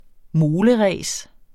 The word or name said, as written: moleræs